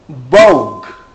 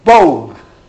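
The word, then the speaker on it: Bog (Бог): Bug, I pronounce it? – Oh. – Pronounce it? Bog. Bog. Thank you.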